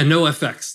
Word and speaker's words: NOFX: So it's four letters, folks. N-O-F-X, so no FX.